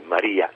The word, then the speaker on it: Maria: Anche se l'accento è posto diversamente, cioè Maria e Mario, in realtà non c'entrano nulla.